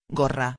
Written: gorra

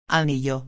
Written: anillo

